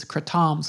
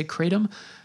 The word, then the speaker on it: kratom: but I think the proper way to say it is kratom. Some people say kratom.